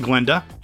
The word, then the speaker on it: Glinda: Now, not for nothing, but current Glinda, the G is silent,